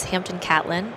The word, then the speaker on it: Hampton Catlin: Joining me right now is Hampton Catlin.